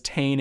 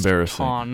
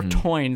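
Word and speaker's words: Táin: Because they spelled it without the accent and they pronounce it as Tane in one of the movements of the song, whereas it's actually pronounced Tán because it's got the little accent over the A, or Toyn, some people say, because of the I that's in there.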